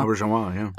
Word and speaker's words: Auberjonois: I mean, there's the quote from, I cannot pronounce his name, I'm sorry, René Auberjonois. – Auberjonois, yeah.